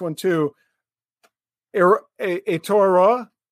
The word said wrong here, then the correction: Aotearoa: I even watched the video, mate, to get it right, and I still butchered it. – Aotearoa. – Aotearoa. Okay. I didn't even get it right.